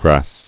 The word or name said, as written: Grassing